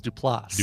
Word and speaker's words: Duplass: I don't know where I'd heard that it was pronounced Duplass, but he is correct. It is Duplass. – Duplass. Duplass. – With the emphasis on the last syllable.